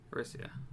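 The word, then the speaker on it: Arisia: I think it's spelled A-R-I-S-A. – It's A-R-I-S-I-A. – Okay. – Orisia.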